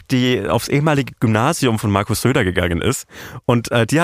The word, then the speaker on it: Söder: Kannst du nochmal richtig schön Markus Söder aussprechen? Bitte? Markus Söder. – Ja, genau so nehme ich.